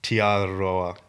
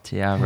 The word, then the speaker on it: Te Aroha: Te Aroha. – Te Aroha. Well, it's got a little tongue roll in it.